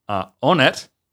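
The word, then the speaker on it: honnête: Onet is a Middle French, late Latin, and this is for honest or honorable. So it's H-O-N-N-E-T-E. – Okay. – But with, obviously, you're kind of the silent H.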